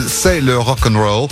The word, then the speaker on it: C'est le rock and roll: I guess I really should give it its proper French pronunciation. C'est le rock and roll. Plastic Bertrand.